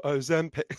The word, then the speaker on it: Ozempic: So the correct pronunciation is Ozempic. Sorry, you don't know, that's the extremely annoying jingle in the adverts. It's Ozempic, yeah.